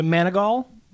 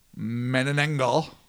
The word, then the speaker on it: Manananggal: The Manigal? – Manananggal.